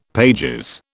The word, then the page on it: pp: British English